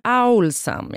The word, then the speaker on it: Owlsam: Det är alltså en lyssnare som egentligen inte ger sitt namn, utan det kommer från då ett e-postkonto som heter Olsam. – Mm. – Olsam Music. – Kanske Owl. – Owlsam, ja. – Som är uggla. – Jag visste ju att det var uggla, jag uttalade det fel.